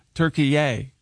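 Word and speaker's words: Türkiye: So he immediately demanded that it be pronounced Turkey-yay.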